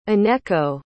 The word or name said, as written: Aneko